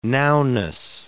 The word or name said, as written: nowness